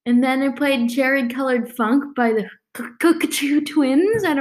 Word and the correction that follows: Cocteau: Cocteau Twins. They're Scottish, but with a French name.